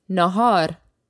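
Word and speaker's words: nāhār (ناهار): Lunch is nāhār.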